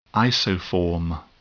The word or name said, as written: isoform